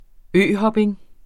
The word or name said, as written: øhopning